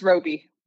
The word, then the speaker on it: Roby: It is Tema. It's just Robbie.